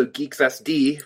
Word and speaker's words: Guix: It's spelled G-U-I-X, which makes most people, including me, initially think that they should pronounce it GUIX, but it's French, or the main author is French, so hence the name Geeks.